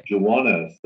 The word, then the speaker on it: Juwanna: And also, an important distinction I want to make, Juana is spelled J-U-W-A-N-N-A. Is that correct? – Yes. – Okay. – Juana. So that when you say Juana Dewitt, you get it? – It sounds exactly like it's meant to sound, wink, wink.